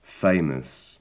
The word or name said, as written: famous